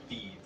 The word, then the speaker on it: Fydd: And that's the first name, and the last name is F-Y-D-D. – F-Y-D-D. How'd you pronounce it? – Thieve. – Yeah, Thieve. Yeah. It does not sound like it. – That's how Welsh works, alright?